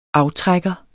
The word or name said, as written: aftrækker